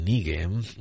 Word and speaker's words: Knigge: Eh, y el apellido se escribe V-O-N y después K-N-I-G-G-E. El señor von Nigge no solamente era afín a estos conceptos de sabiduría y de eh, rebelión contra el status quo.